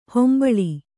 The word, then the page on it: ಹಂಬಲು: hambalu